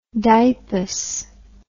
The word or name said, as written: dipus